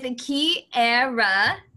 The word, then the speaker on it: Kiarra: All right, today we are talking with Kiarra.